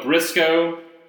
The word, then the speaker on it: Briscoe: And I'm going to go with Briscoe because it rhymes with Crisco, and I love fried chicken.